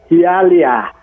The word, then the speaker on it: Hialeah: Đó thì cái thành phố mà muốn giới thiệu với cái ngày hôm đó nó gọi là đọc là Hialeah.